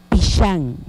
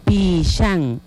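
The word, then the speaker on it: pixan: Corazón, en lengua yucatán decimos pishan, pishan. Empezamos con la primera letra, la letra P, la letra I, la X, la A y la N.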